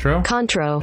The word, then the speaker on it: Cointreau: Cointreau? – Contro.